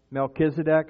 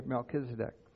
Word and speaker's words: Melchizedek: He became a high priest forever in the order of, have fun with the name, Melchizedek is the way I pronounce it. I wasn't there when he got named, so I don't know how the official pronunciation is, but I say Melchizedek.